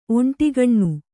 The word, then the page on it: ಒಂಟಿಗಣ್ಣು: oṇṭigaṇṇu